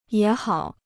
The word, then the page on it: 也好: yě hǎo